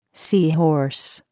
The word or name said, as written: sea horse